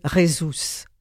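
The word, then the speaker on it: Jesús: Toujours vaillamment aidé par son père, bon, ça s'écrit Jésus, mais il faut prononcer Jesus, c'est bien cela?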